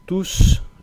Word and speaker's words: tous: Todos, tous, es una excepción porque hemos visto que la S final normalmente no se pronuncia, pero en este caso sí, es una excepción.